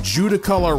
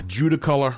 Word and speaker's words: Judaculla: Warren, and David Weatherly brought up Judicula, and Judicolor Rock. Now, that is spelled J-U-D-A-C-U-L-L-A.